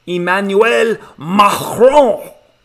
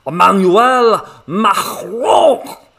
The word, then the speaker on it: Emmanuel Macron: You have to say it like that. If you're speaking French, you want to show respect. So you got to build up the phlegm. Emmanuel Macron. You make it sound like you have macaroni and cheese stuck in the back of your throat.